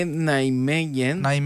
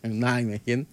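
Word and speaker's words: Nijmegen: Και είναι Νάιμεγεν. Εντάξει, Άννη μου, συγγνώμη. – Μια φορά να διορθώσω εγώ μια φορά. – Δεν είπαμε την προφορά, οκ, συγγνώμη. Συγγνώμη, δε μας για την Νάιμεγεν. Νάιμεγεν. Οκ.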